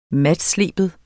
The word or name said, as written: matsleben